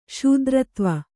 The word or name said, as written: ಶೂದ್ರತ್ವ